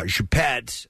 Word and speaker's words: Choupette: He had a cat named, and I tell me if this is correct, Nick, you're good with French pronunciations, Choupette? – Yes. – C-H-O-U-P-E-T-T-E? – Yeah, I think it's a term of affection.